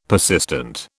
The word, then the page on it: persistent: British English